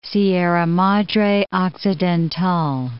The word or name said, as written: Sierra Madre Occidental